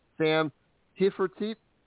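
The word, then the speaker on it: Sam Hafertepe: Starting on the pole looks like Matt Campbell, Lance DeWeese, Freddie Raymer, Lucas Wolf, Danny Dietrich, Justin Peck, Sam Heffertit. Did I say that right? – You got it. It's pretty close to being right.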